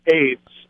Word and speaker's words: Ades: Their name is ADES, pronounced AIDS, okay? – Yeah.